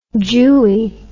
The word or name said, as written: jewie